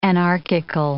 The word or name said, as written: anarchical